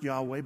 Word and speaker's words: Yahweh: We think it's pronounced Yahweh, but we don't exactly know.